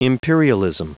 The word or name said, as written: imperialism